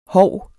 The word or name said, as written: hov